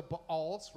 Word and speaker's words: Baal: So he chopped down the altars of the Baals, right? So in case you're like, what? How do you pronounce two A's? Baal, all right?